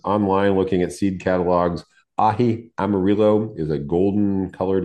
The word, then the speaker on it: aji: And you pronounce it ahi, but it's really spelled A-J-I, isn't it?